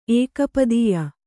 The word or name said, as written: ಏಕಪದೀಯ